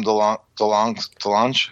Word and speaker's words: DeLonge: And I think that was through Tom DeLonge.